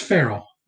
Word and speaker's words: Ferrell: Is it Ferrell or Pharrell? – It's Ferrell. Hey. You've just been pronouncing that just to screw with me, haven't you? – Ferrell, yeah. – Yeah, Ferrell. Will Ferrell. Will Ferrell. – So he's more like Ferrell, like a feral animal.